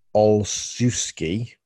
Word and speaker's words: Olszewski: Jacoby Myers had dropsies, and then you're looking at Gunnar Oslowski, Damier Bird. And Matthew Slater and Gunnar, in fact, I've said his name wrong. Gunnar Olszewski is the fifth receiver.